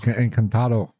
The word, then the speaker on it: Encantado: You pronounce that. You can pronounce it better than me. – Está el Encantado. – Encantado. – Yes.